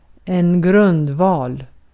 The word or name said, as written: grundval